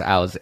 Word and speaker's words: Auws: This guy's Aus. A-U-W-S. – That is not how I spelled it. – Yeah. – A-U-W-S. – Yeah. – No E either. – Pronounced like house without the H, he says.